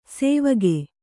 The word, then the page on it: ಸೇವಗೆ: sēvage